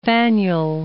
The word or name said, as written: Faneuil